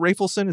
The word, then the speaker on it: Rafelson: And so based on that success, Bob Rafelson, are you pronouncing it Rafelson? Is that what we're going with? I like that. That's what I'm saying, yeah. That's what I heard Jack Nicholson say in an interview, so I assume he's right because they were friends.